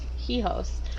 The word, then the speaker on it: hijo: Hijos. I haven't taken Spanish in a year. – It's just E. Don't pronounce the H, I think.